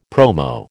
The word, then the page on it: promo: American English